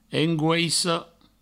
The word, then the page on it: angoissa: angouisso